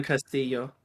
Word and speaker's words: Castillo: Esposito as Antonia Castrello? – Yeah. – Right? – Anton Castillo. – Castillo, thank you.